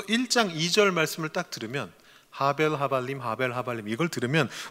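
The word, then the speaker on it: 하벨 (הֲבֵל): 그래서 이게 헤벨이라고 발음이 되지 않고 하벨이라고 발음이 됐습니다. 사실 저처럼 발음하면 안 되고 R을 길게 발음하면 안 됩니다. 하벨, R을 짧고 약하게 발음을 해야 됩니다.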